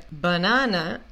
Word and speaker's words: banana: Então, a única A que a gente pronuncia corretamente é esse A no meio que pega o stress, que carrega o stress, a sílaba tônica. Banana, ok? A primeira A e a última A tem som de banana, banana, ok?